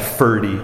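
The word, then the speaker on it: Forde: He said, it's pronounced Ferdy. I have that confirmed from people living in Minnesota.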